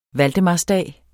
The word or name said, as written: valdemarsdag